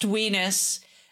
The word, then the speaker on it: WNIS: Because this is episode 1, we should probably remind everyone, WNIS, World's Nerdiest Improv Show, pronounced weenus.